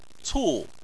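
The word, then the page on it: cu: tone 4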